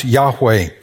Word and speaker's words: Yahweh: It's the four letters that we pronounce Yahweh.